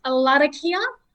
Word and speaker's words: Larrakia: So you might know it, or people out there might know it as Larrakia and Arunda. However, it is pronounced Larrakia and then Arunda. So both of those is the roll of the R's. You've got to make sure you roll those R's.